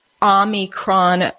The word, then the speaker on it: Omicron: Omicron, he threw an N in there, but it's actually, I guess it's pronounced the Omicron variant, which is the 15th letter of the Greek alphabet.